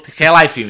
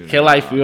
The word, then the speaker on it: Khelaifi: Tak to vlastne aj popisujú podobne, či je to prezident PSG Nasser Al-Khelaifi, tak sa volá, mám také, dobre som ho vyslovil. – Khelaifi, myslím. – Khelaifi, ok. Tak on povedal,